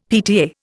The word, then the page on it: PTA: Indian